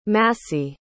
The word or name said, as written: massey